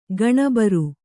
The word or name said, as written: ಗಣಬರು